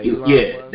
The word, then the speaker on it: Elon Musk: And once your boy, the billionaire, Elon Musk, am I saying it right? – Yeah, Elon Musk.